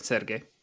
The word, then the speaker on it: Sergei: He was, after killing his brother, but Sergi? Sergei? How do you say it? Sure. – That sounds about right. Sergei.